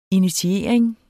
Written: initiering

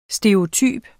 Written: stereotyp

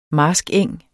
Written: marskeng

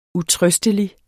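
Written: utrøstelig

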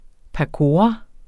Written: pakora

